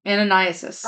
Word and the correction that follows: Ananias: Okay. No, there's no sis. Ananias.